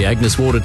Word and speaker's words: Agnes Water: Heading down to Agnes Waters. – Right. It's pronounced Agnes Water.